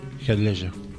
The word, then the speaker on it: Heath Ledger: Actores que trabajan en la película: Morgan Freeman, Michael Caine, Gary Oldman y el señor Heath Ledger,